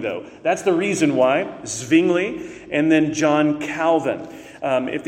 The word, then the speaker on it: Zwingli: Zwingli is one way to pronounce this. The W in this era and in these European cities will often have a V sound.